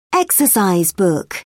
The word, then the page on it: exercise book: British English